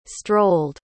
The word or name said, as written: strolled